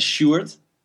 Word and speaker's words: Sjoerd: Let's call me Stuart, because my real name is my real name is Sjoerd, like S-G-O-E-R-D, only that's to pronounce that in English is a little bit difficult, so call me Stuart.